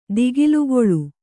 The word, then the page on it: ದಿಗಿಲುಗೊಳ್ಳು: digilugoḷḷu